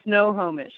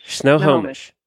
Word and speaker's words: Snohomish: Snohomish. – Snohomish.